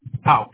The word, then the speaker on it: Houck: Houck, H-O-U-C-K. – All right. Can you spell that for me again? – H-O-U-C-K.